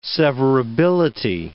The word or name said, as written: severability